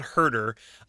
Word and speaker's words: Huerter: I actually did a podcast earlier today with someone who was calling him, I believe it was Huerter. It's Kevin Huerter, which it's another weird spelling versus pronunciation. So if you don't do all, trust me, I think I was saying it wrong for the first like 2 weeks, and people kept correcting me, but it is Kevin Huerter.